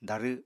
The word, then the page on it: darw: apatani